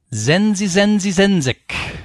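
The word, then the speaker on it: zenzizenzizenzic: That is spelled Z-E-N-Z-I. Z-E-N-Z-I-C. Zenzi Zenzi Zenzik.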